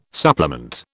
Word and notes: supplement: British English

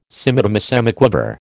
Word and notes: semidemisemiquaver: American English

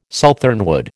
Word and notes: southernwood: American English

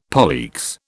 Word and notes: poleaxe: British English

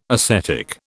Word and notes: ascetic: British English

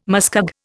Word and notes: muskeg: Indian

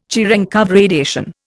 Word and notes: Cherenkov radiation: Indian

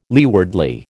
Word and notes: leewardly: American English

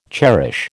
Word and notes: cherish: American English

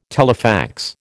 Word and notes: telefax: American English

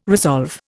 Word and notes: resolve: Indian

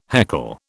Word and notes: heckle: British English

